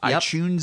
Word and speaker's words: iTunes: That hard T, pronounce it like a CH. iTunes.